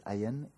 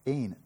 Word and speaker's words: Ayin: Ayin, A-Y-I-N, Ayin or Ayin, I've heard it, Ayin, that's okay, Ayin, thank you, because I've heard it that and I didn't quite know what was correct. Ayin, an ultimate reality that is accessed by means of the practice of Kabbalah.